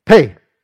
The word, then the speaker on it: phat: where you suddenly say, pay! And you just sort of use, it's spelled P-H-A-T.